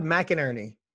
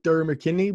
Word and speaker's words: McInerney: Todd McInerney. That's how you say his name. – McInerney. – I've been reading it forever. And I was like, Durr McKinney, but McInerney. That's right.